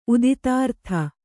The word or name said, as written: ಉದಿತಾರ್ಥ